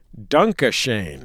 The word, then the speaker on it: Dunkashane: The restaurant would be named Dunkashane, pronounced as the Wayne Newton song, so Americans would understand the joke.